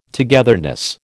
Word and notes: togetherness: American English

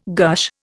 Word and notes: gush: Indian

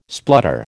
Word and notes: splutter: American English

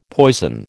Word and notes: poison: American English

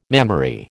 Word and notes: mammary: American English

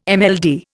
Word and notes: MLD: Indian

